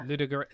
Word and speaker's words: Ludogorets: We'll go for Ludogorets, even though I can't say it right, yeah. – Ludogorets.